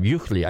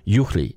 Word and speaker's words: Juchli: I said it right this time. Juchli. I couldn't say it right during the whole show. And I should know better because I can speak German. You know, Bernard Juchli.